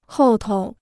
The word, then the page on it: 后头: hòu tou